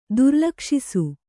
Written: ದುರ್ಲಕ್ಷಿಸು

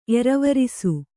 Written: ಎರವರಿಸು

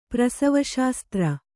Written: ಪ್ರಸವಶಾಸ್ತ್ರ

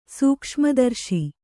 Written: ಸೂಕ್ಷ್ಮದರ್ಶಿ

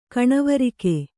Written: ಕಣವರಿಕೆ

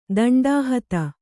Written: ದಂಡಾಹತ

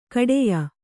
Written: ಖೇಡ್ಯ

